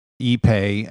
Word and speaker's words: Ipe: That's spelled I-P-E. It's pronounced ePay, and yes.